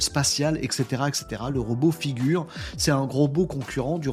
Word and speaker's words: Figure: c'est Figures, euh, c'est chiffres, c'est pas Figure, Figure, je crois. Bref, ça s'appelle Figure, dit prononcé en français.